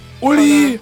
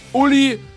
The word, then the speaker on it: Uli: Uli. – What's your name? – Uli. I spell it. U. – Okay. U. – L. – L. – I. – I. – Uli.